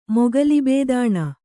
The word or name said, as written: ಮೊಗಲಿಬೇದಾಣ